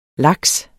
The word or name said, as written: laks